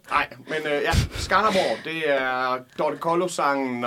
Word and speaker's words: Skanderborg: Øh, du er fra Skanderborg. – Det stemmer, og du udtalte det så dejligt korrekt.